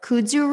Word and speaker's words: kudzu: So you have chicory root, Jerusalem artichoke, kudzu root. Am I saying that right? – Yes, you are.